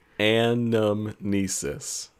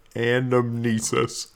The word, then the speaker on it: anamnesis: Is an act of amnesis. Did I say that correctly? Is an act of remembering. – Exactly. – Wait, one more time. Say it for me. – Anamnesis. – Anamnesis. Okay.